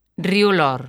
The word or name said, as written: Río Lor